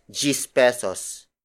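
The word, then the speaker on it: diyes pesos: such as gis pesos. Gis pesos. Gis pesos. Gis pesos is spelled as D-I-Y-E-S space P-E-S-O-S.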